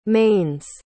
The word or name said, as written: mane's